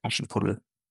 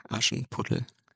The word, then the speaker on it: Aschenputtel: No, it's Aschenputtel. – Nochmal. – Aschenputtel.